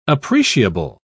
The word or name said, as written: appreciable